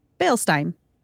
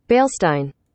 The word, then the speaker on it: Bielstein: I want to say thank you to Caitlin Rogers, James Reed, Ryan Humans, Donald Lampley, David Funk, Joseph, and Tyler Bielstein. Bielstein. – How do you spell it? – B-I-E-L-S-T-E-I-N. So I think E-I-N is Stein. Is Stein. And then B-I-E is Bielstein. Bielstein.